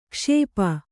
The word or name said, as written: ಕ್ಷಪೆ